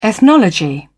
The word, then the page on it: ethnology: British English